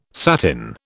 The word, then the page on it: satin: British English